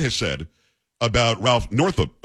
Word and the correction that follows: Northam: Northup? Let me make sure that I have it right here. I don't want to mispronounce it because then I'll be Biden. Northam, N-O-R-T-H-A-M.